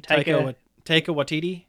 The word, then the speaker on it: Taika Waititi: Taika Waititi? – Yeah.